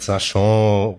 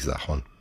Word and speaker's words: Sachon: Der kommt aus dem Französischen, das hast du schon richtig ausgesprochen, aber mittlerweile ist er über mehrere Generationen eingedeutscht. Also ich glaube, es ist alles richtig. Robert Sachon, Robert Sachon, üblicherweise sage ich Sachon.